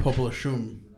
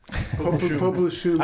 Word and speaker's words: Popelouchum: Popelouchum. They say it right? – Yes. – Yes. – Popelouchum.